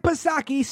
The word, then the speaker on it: Psaki: Now, if missing the numbers wasn't bad enough, when asked about it, Jen Circleback Psaki, Silent P, of course, she says, well, I can't really talk about that until maybe around 9:30 in the morning.